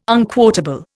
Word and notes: unquotable: Indian

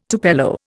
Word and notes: tupelo: Indian

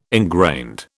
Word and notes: ingrained: British English

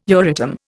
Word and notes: Euratom: Indian

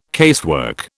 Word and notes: casework: British English

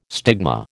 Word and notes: stigma: American English

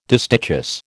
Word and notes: distichous: American English

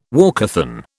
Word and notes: walkathon: British English

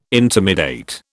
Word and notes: intimidate: British English

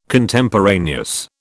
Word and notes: Contemporaneous: British English

